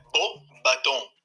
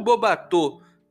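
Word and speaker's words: Beauxbatons: Então, como vocês acabaram de ouvir do próprio Lauro que me mandou esse áudio, é bobatom e não bobatô. Porque senão você estaria falando belos barcos.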